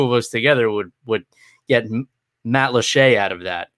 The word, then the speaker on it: Matlacha: and the pronunciation is French, but the word is Native American. It's a weird thing. So it's actually Matlaché.